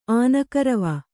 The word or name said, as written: ಆನಕರವ